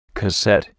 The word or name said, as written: cassette